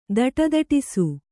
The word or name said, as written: ಧಡಧಡಿಸು